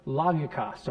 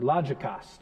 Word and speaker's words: logikos: and it's this word logikos or logikos, depending on how you want to pronounce the G of in the Greek.